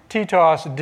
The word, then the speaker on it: Titos: Titos, as his name would have been pronounced. Well, I lied. Titos did not write that.